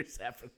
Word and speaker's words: Safran: Peter Safran.